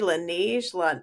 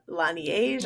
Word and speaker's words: Laneige: L-A-N-I-E-G-E. Laneige. Laneige.